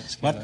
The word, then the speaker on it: Scala: Scala, is that the way you pronounce it? – That's right, Scala.